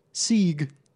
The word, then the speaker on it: segue: Sieg. – That's how it sounds when you pronounce it. For those who don't know, it's S-E-G-U-E, not S-E-G-W-A-Y.